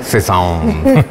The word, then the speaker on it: Cézanne: Cézanne.